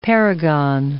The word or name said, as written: paragon